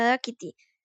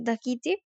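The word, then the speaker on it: Dákiti: Eh, bueno, lo primero que tengo por decir es que está escrito, al menos en Spotify, con acento en la o sea que se pronunciaría Dakiti.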